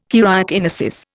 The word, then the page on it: karyokinesis: Indian